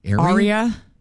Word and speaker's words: Aria: Oh, how do you pronounce A-R-I-A? – Aria? – Aria.